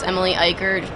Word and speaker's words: Eicher: It's Emily Eicher. Last name is spelled E-I-C-H-E-R.